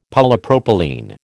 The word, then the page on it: Polypropylene: American English